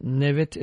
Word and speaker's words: Nevit: Nevit is spelled N-E-V-I-T. Kodallı is K-O-D-A-L-L-I.